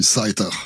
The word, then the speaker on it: Seiter: Euh, comment ça se prononce euh, votre nom Céter, c'est comme ça Ouais, ça va bien faire comme ça. Sinon, à l'allemande, c'est bien aussi. Zeiter.